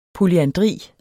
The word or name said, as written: polyandri